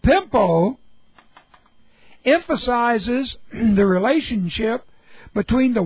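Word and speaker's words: pempo: And that word is pimpo. Pimpo. P-E-M-P long O.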